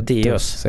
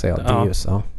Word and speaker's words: Deus: Ja, det är så du uttalar det. – Deus. – Deus. Det är latin.